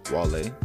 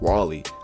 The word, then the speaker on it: Wale: Wale? Yeah, I think I said that right. I don't know why I thought it was Wally. I was about to say Wally like it was a Pixar movie.